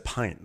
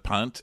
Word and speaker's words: pint: So then, you know that, by the way, she said pint, not punt, right? – She pronounces it punt, and I didn't know what it meant.